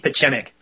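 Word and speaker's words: Pieczenik: According to Dr. Steve Pizienek, I can never say his name. – That's Steve Pichenik.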